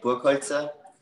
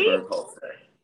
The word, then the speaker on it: Burkholzer: So in German, you would say Burkholzer, but Americans usually say Burkholzer.